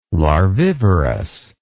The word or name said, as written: larvivorous